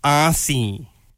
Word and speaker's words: Aussie: Aussie. – You're an Aussie. Oh, that's so cool. – Why do I always have to, like, really strong the S in it?